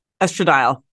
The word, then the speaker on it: estradiol: How do you say that? – Estradiol. – Estradiol patch and some progesterone.